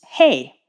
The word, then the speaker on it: He: It's H-E, and it's pronounced hey, like H-E-Y.